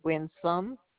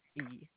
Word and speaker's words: Winsome: My name is Winsome Azan. Would you like me to spell it for you? – Sure. – First name, Winsome.